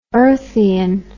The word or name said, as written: earthian